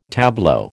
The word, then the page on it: tableau: American English